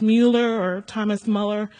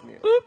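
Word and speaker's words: Müller: It was Thomas Muller. I don't know how to pronounce his last name, M-U-L-L-E-R. He opened orphanages in England and prayed. – George Mueller. You had the last name right.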